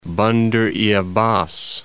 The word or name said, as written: Bandar-e-Abbas